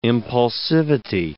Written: impulsivity

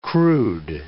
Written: crude

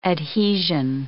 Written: adhesion

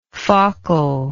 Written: faucal

